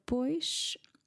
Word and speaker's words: pois: This word pois by itself or with a, a longer pause before acredito, then we pronounce the S as a sh. But because acredito starts with a vowel, the S is now stuck in between two vowels. I'm going to sound it as a Z.